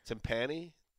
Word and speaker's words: timpani: timpani? Did we ever get the correct pronunciation on that? – It's timpani.